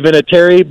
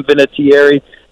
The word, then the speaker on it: Vinatieri: So, um, yeah, it's, we, we say Vinatieri, but I've heard a lot of people pronounce it with more enunciation. So if you want to call me Adam Vinatieri, knock yourself out.